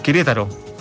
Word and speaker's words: Querétaro: El Querétaro, sí, ahí lo dije bien, sí, de súper bien, de México.